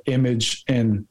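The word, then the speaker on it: Imagen: And then Google's, I guess, rival company known as Imagine, or I think it's pronounced Imagine, but it's like image in.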